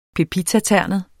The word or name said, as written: PEPITATERNET